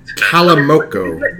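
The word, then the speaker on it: Calimocho: It's pronounced... Calamoco. – Bad red wine? – It's called Calamoco in Spain.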